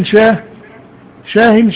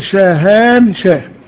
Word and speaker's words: شاهان شاه: وشاه شاه، شاه شاه هذا اختصار في النطق اللغوي الدارج، لكنه تقال شاهان شاه، شاهان شاه، لأن الألف والنون في هؤلاء الناس الذين هم في القوقاز والشيشان وروسيا وطالبان وأفغانستان وكله معناها ألف لام مؤخرة.